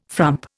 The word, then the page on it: frump: Indian